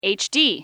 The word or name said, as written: hd